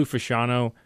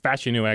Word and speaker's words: Fashanu: Olu Fashanu is probably Fashanu, actually, is how you pronounce it, is, you know, probably the top left tackle, at least if you get past Joe Walt, who won't be there at 14.